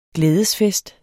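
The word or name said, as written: GLÆDESFEST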